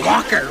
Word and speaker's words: Walker: Walker rhymes with talker.